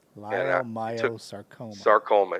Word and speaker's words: leiomyosarcoma: Leiomyosarcoma.